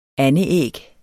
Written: andeæg

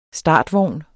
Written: startvogn

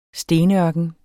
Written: stenørken

